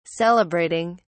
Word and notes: celebrating: British English

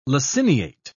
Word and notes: laciniate: British English

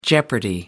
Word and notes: jeopardy: American English

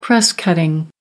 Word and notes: press-cutting: American English

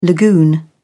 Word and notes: lagoon: British English

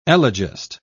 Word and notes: elegist: British English

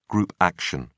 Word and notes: group action: British English

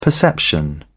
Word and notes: perception: British English

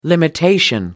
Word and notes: limitation: American English

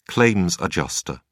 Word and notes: claims adjuster: British English

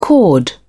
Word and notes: chord: British English

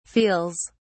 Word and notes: feels: British English

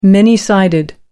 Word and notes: many sided: American English

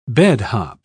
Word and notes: bedhop: British English

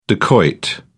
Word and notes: dacoit: British English

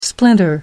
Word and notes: splinter: American English

